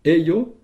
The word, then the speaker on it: ejo: Alors, en fait, dans ces deux mots, il y a un nouveau suffixe, eio, qui s'écrit E-J-O, Eyo, c'est un endroit.